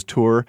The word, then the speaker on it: Tor: Did you see how I pronounced Tor? – Yeah. – It's spelled T-O-R. And I asked him. I didn't want to mess this up.